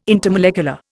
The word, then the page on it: intermolecular: Indian